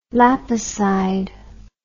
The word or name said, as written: lapicide